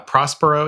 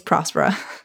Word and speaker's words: Prospera: Okay, so, but the accent is on the O. So, like if you know, and it's a Spanish-speaking country, so I think it's Prospera.